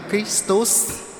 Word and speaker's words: Christus: Chữ Kitô tiếng Latin là Christus, tiếng Latin là Christus.